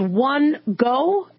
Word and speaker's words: One Goh: He was a former nursing student, 43-year-old One Go, if I'm pronouncing that right, O-N-E-G-O-H.